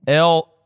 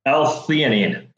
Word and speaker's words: L-theanine: L-theanine. – Say it again. Sorry. – L-theanine. – L-theanine. – Yep, T-H-E-A-N-I-N-E. – I just want to make sure I'm pronouncing it right there and everybody can hear you.